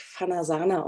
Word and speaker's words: Fanajana: einer der Gründerinnen von Fanayana. Magst du den Hörerinnen mal erzählen, was Fanayana, Fanayana äh, ist und äh, wofür ihr euch einsetzt, was ihr so macht? – Vanayana äh, wird eigentlich Fanasana ausgesprochen ah.